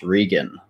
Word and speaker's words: Regan: Regan.